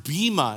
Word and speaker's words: bema: The word judgment right there in the Greek is pronounced bema.